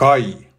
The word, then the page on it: KAJ: Swedish